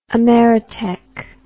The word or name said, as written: Ameritech